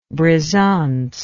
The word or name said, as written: brisance